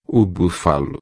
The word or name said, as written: bufalo